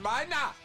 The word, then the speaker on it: Meine: Ah, it's spelled M-E-I-N-E, but he's right. It's Klaus Meiner.